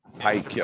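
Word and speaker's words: Paik: Yep, Pike.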